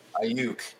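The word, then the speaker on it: Aiyuk: Ayuk, he spent a ton of time with Lance in the offseason.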